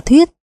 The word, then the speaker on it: thuyết: Chú giải, thuế thường đọc là thuyết.